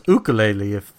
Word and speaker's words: ukulele: It's pronounced ukulele if you want to be official. – Ukulele. – If you go to Hawaii, that's how they pronounce it.